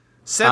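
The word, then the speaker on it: Szeth: So then we cut to, 4,500 years later, like I said, with, is it pronounced, I just pronounce it Seth. – That's how I think it's S-Z-E-T-H. Did you get on the audiobook, is it just Seth? – It's Seth, but they put, like, some Zeth.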